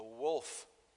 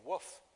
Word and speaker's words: wolf: I'm trying to pronounce that word correctly. My wife and daughter have always given me, I always used to say woof, and they're like, no, that's what a dog sounds like.